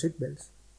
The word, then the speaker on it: Seatbelts: Eh, el trabajo de Yoko Kano eh, y, los seatbelts, y los, a ver si lo digo bien, eh, seatbelts o algo así, ¿no? ¿cómo se dice? Eh, sí, lo has dicho bien, los seatbelts.